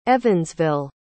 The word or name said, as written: evansville